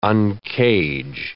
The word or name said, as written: uncage